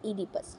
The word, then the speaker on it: Oedipus: ईडिपस की spelling है OEDIPUS, लेकिन हम इसे प्रोनाउंस करते हैं एज किंग ईडिपस.